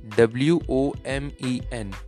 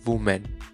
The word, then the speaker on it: women: Next word is W O M E N. Correct pronunciation is women, not woman.